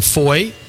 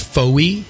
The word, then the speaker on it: Fowey: And we're about 6 kilometers from Foy, which, if you read it out, you'd pronounce Foy. – Yeah, F-O-W-E-Y.